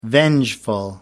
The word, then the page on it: vengeful: American English